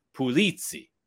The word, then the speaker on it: Pulizzi: So the Americanization of my name is Pulizzi. So that's how you would pronounce it. Now, when you go over to Sicily, Marsala, Sicily, it's Pulizzi.